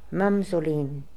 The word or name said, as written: Mamzolin